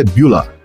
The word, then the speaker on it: Bueller: A pronúncia correta é Bueller. Bueller.